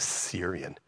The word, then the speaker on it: Syrian: The servant of Elisha, the man of God, said, look, my master has spared Naaman, this Syrian, you have to say it like this, it's Syrian, while not receiving from his hands what he brought.